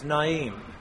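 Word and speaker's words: Nain: And this is shown when, as he goes into the little town of Naim, and of course that's oftentimes mispronounced, but it's Naim, a funeral procession is coming out.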